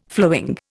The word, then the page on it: flowing: Indian